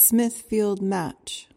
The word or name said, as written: smithfield match